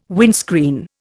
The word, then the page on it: windscreen: Indian